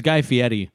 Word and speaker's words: Guy Fieri: His name is Guy Fieri.